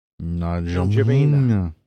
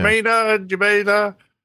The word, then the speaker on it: N'Djamena: N'Djamena. – Oh, thank you. You know how to pronounce that. – Yeah, I've spoken to them on the HF often enough. – Oh, okay. – N'Djamena.